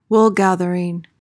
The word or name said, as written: woolgathering